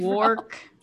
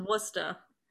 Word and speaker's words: Worcester: The first one's Worcester. – What? – How the fuck do you get that out of those letters, Lexi? – Worcester?